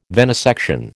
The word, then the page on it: venesection: American English